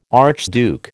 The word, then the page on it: archduke: American English